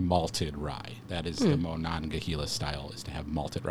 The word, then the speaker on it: Monongahela: We actually write it out phonetically. Monongahela. I did say it right.